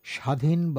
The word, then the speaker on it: স্বাধীন: শব্দের শুরুতে বফলা যুক্ত হলে তার কোনো পৃথক উচ্চারণ বাংলায় ধ্বনিত হয় না, তাই এর উচ্চারণ স্বাধীন বা স্বাধীনতা।